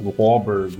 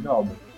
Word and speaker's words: Glauber: Tem uns caras que falam Glauber. Mas eu ensinei, o pessoal do Fugdag, eu ensinei, ele me chama de Glauber. – Mas eles conseguem pronunciar Glauber? – É, sim. É que você fala que nem coruja, que é Al, né? Aí fala, Glauber.